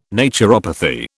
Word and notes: naturopathy: British English

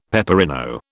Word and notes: peperino: British English

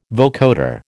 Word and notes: vocoder: American English

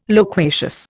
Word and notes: loquacious: Indian

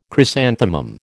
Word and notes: chrysanthemum: American English

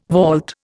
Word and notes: vault: Indian

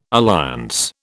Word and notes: alliance: British English

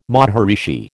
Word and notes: maharishi: American English

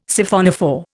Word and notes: Siphonophore: Indian

